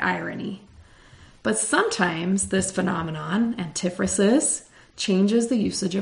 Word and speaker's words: antiphrasis: I want to say antiphrasis or antiphrasis or something, but it's antiphrasis is the correct pronunciation.